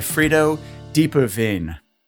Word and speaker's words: Frido Diepeveen: I'm delighted to be joined today by Frido Diepeveen.